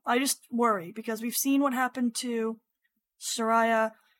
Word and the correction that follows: Shayura: I said her name wrong again. Frick frack. Um, Shayora and we've seen what happened to uh, Aisha.